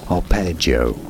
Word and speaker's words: arpeggio: Arpeggio in English. Arpeggio. – There. – Thank you. – So it's a soft G.